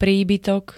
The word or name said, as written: príbytok